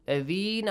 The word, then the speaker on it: الذين: ولا تحسبن الذين قتلوا. – الذين also a long vowel sound.